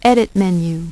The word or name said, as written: Edit menu